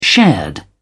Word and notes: shared: British English

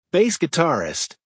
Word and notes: bass guitarist: American English

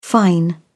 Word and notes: fine: British English

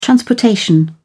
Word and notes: transportation: British English

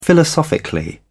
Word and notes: philosophically: British English

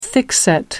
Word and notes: thickset: American English

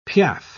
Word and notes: piaffe: British English

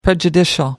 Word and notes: prejudicial: American English

